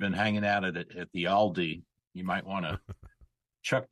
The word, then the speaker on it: Aldi: How do you pronounce Aldi.